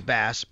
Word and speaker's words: Bass: For Tyler Bass, it literally says pronunciation pronounced like the fish.